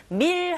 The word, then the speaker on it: meal: 이렇게 발음하시고, meal 할 때 여러분 그냥 meal이 아니라 meal 하고 혀끝이 윗니 뒤로 붙어주셔야 돼요. L 사운드, 다크 L 사운드잖아요.